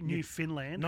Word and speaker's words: Newfoundland: In say it right, God, for God's sake, say it right. – Oh, sorry, Newfoundland. – No, she'll kill both of us. – Newfoundland. – Newfoundland. – Newfoundland. – So she always says, like, understand Newfoundland.